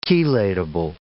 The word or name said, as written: chelatable